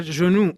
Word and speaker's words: genou: Justamente, Asbel, dos estudiantes no estaban seguros de la palabra genou por la pronunciación del monitor, puesto que ellos tenían la costumbre de pronunciarla de manera castellanizada. Sin el sonido característico de la hirante francesa, además de la E abierta del castellano.